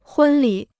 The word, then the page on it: 婚礼: hūn lǐ